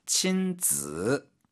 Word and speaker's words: 亲子: 친즈, 발음 즈, 이게 삼성어로 발음해야 됩니다.